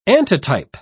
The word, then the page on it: antitype: British English